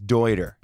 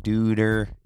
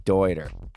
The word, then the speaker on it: Deuter: If you haven't, it's Deuter. It's the man's last name. It's not Deuter. It's Deuter.